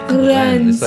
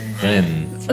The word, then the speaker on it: Rennes: Is it Rennes? – How noble. – Rennes? – Rennes. – Rennes? – I think it should be Len. The S is silent. – Rennes. – It's like Cannes, but it's Rennes.